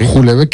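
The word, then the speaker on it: Houellebecq: Julebeck, que la pronunciación correcta es Julebeck.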